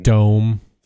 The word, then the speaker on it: FanDome: Fandome, say it right.